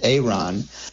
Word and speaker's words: Aaron: The name is Aaron, but he pronounces it Aaron.